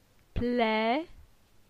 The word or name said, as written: Ple